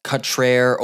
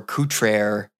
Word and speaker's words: Cutrer: I've been informed that T. Tommy Cutterer's last name is actually pronounced Cutrer or Coutrer.